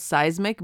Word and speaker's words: Cysmic: it's called Seismic, but it's not spelt like a seismic wave. It's a C-Y-S-M-I-C. So it's spelt a little differently.